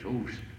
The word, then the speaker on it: hooves: We often think of it as hoofs, but I think the proper pronunciation is hooves.